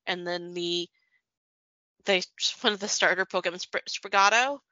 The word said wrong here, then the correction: Sprigatito: Sprigatito. – Yes, Sprigatito, what you said.